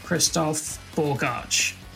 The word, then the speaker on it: Christof Bogacs: Joining me today is comic book creator and content writer, Christoph Borgarch.